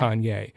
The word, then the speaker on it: Kanye: Only Jay-Z or Kanye, I just learned that's how you're supposed to pronounce it. – Oh. – Jeff Basker interview, he's like, yeah, no, everyone in Kanye's camp says yeah. Kanye.